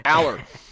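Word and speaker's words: Allar: Although I did have a one of our listeners is from Ohio, and he gave me a pronunciation help. Drew. – Aller. – Is the way he. – Aller.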